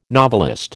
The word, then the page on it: novelist: American English